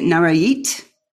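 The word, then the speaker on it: Nayarit: You sent us a copy of your new book, A Place at the, and I would, correct me if I say this wrong, is it Narayit? – Yes.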